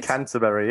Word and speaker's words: Canterbury: Canterbury, yes. – Did I say that right? – Very correct.